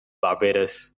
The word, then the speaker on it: Barbados: So shout out to all the Caribbean, you know, talking about St. Vincent and the Grenadines, St. Lucia, Barbados, and it's Barbados, not Barbados. Everybody from like the U.S. says Barbados, it's really Barbados. That's how they say it here.